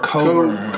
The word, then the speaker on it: Cockburns: They probably pronounce it Coburns because they realized the first word was cock. – It's because they're Portuguese and that's how you say it.